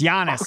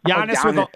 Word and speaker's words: Giannis: I think we should teach you how to say his name. – It's Giannis. – Okay. – Giannis, Giannis like with a Y. Giannis.